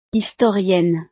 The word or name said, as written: historienne